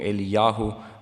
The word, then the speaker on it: Eliyahu: Wisst ihr, Elia ist hier ein Sinnbild für den Christen, denn Elia heißt auf Hebräisch ausgesprochen, Eliyahu, mein Gott ist der Herr.